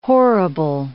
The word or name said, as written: horrible